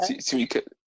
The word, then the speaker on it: Tsimikas: And then I think obviously left back will be Tsimikas, if I said it right. – Yeah. – Tsimikas.